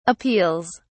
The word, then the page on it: appeals: British English